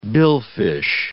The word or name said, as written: billfish